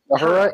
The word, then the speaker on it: Uhura: Uhura, is that how you say it? – Uhura and Sulu.